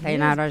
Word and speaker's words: Thaynara: Recentemente, ah, esqueci de perguntar como se fala o nome dessa moça, é Tainara OG. – Tainara OG. Então acertei.